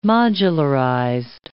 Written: modularized

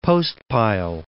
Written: Devils Postpile

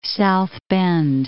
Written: South Bend

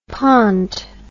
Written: pont

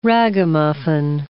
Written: ragamuffin

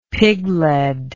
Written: piglead

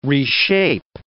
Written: reshape

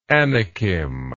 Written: anakim

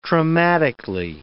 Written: traumatically

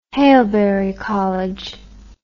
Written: Haileybury College

